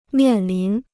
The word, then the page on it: 面临: miàn lín